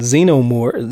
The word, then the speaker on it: xenomorph: And out of nowhere, the freaking xenomorph, is it xenomorph? Am I saying this right? – Xenomorph, yes, sir.